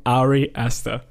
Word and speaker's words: Ari Aster: Aber nein, er wird ausgesprochen Ari Aster.